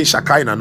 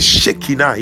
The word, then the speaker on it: Shekinah: You see, most of the times we have been saying Shekinah. No, it's not Shekinah. It is Shekinah. In the Hebrew, it is pronounced Shekinah.